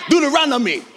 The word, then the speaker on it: Deuteronomy: Deuteronomy, for you scholars.